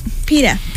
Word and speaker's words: PETA: PETA.